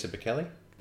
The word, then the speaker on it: Bekele: He set the 5,000 meter world record and the 10,000 meter world record, both the times beating Kenenisa Bekele. – That's the bloke. – That's how the Marathon Training Academy pronounces it. – Okay, well, we'll go with that then.